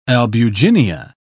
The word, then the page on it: albuginea: British English